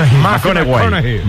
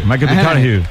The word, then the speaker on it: McConaughey: Mateo McConaughey. – Sí, sí, sí. Sí, sí. – Hay que ir corrigiendo la pronunciación. – McConaughey. – McConaughey.